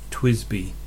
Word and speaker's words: Twisby: So my number two example is from a company, TWSBI, that everybody pronounces Twisby, and it's the Eco model.